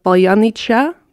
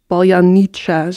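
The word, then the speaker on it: paljanitsja (паляниця): Dat is paljanitsja. Dat is een speciaal traditioneel Oekraïns brood. En Russen kunnen dat eigenlijk niet uitspreken, omdat ze dus paljanitsja zeggen. En niet met die kortere I.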